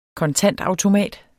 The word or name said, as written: kontantautomat